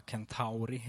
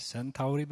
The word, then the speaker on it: Centauri: Den närmaste stjärnan från vårt solsystem kallas för Alpha Centauri, eller Centauri beroende på hur vi uttalar den där bokstaven.